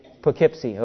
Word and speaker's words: Poughkeepsie: Is it Poughkeepsie, New York? Is that how you say that, Ms. Rosenau? Huh? Poughkeepsie. Okay.